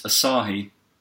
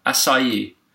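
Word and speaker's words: açaí: I believe this is pronounced Asahi. – The beer? – No. – There's a beer, isn't there, called Asahi? – There is a Japanese beer called Asahi, isn't it? Or are you going to call it Akai? – Akai? I don't know. Yeah, I don't care. – A-C-A-I. – I don't care enough Berry. To pronounce it correctly. – I'm pretty sure it's Asahi beer. Acai bowl, acai, acai, acai, acai. Yeah, it's definitely called acai.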